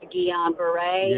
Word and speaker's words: Guillain-Barré: I was going to tell you it's pronounced Guillain-Barré.